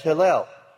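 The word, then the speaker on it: Hillel (הילל): It has nothing to do with Hallelujah, Yahweh, or Halal, or anything else. It's Hillel. It is spelled, as you'll notice, Hey, Yod, Lamed, Lamed. It has Hillel in it. That's Hillel, totally different.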